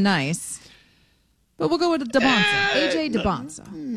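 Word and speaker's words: Dybantsa: I'd say DeBonsa. DeBonsa. – I know. Can we get one pronunciation, please? Because I've heard it both ways for 2 years. So DeBonsa. – DeBonsa. We're going DeBonsa.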